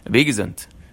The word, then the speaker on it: Abi Gesund: They would say that in New York, New Jersey, you know, sort of previous generation, and they'd say Abi Gesund. Abi Gesund. You know, at least I've got my health. And I was like, oh, that's how you pronounce it.